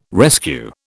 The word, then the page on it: rescue: British English